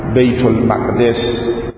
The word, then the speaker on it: بیت المقدس: ما بهش میگیم بیت المقدس و در تلفظ اصلی هست بیت المقدس،